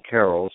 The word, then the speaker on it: Carroll: My name is spelled with two R's and two L's, so that's Tom, the normal spelling, and Carroll, C-A-R-R-O-L-L, two R's and two L's, Write Tom Carroll.